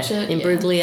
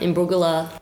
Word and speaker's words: Imbruglia: It's a silent G. – I have been practicing. I will not lie. – How do people do the G very often? – Yeah. Imbruglia. Yeah. Imbruglia.